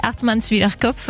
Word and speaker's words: Hartmannswillerkopf: Le jeu, ça va être de faire prononcer Hartmannswillerkopf. – Voilà. – Alors, si vous pouvez le redire très lentement. – Hartmannswillerkopf. – Voilà, c'est pas compliqué en fait. – Hartmannswillerkopf. – Presque, Kopf.